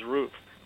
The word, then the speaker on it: Ruf: R-U-F. – Yeah. – That's the German pronunciation, I guess, is Roof, right? – Yeah.